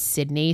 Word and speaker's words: Sidney: But like, the fact that my government legal name is Sydney, spelled the same way, S-I-D-N-E-Y, which is like usually the boy way to spell it, like, I just think it was meant to be, honestly.